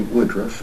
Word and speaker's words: Woodroof: Well, my YouTube is simply my name, Terry Woodruff, and it's spelled W-O-O-D-R-O-O-F.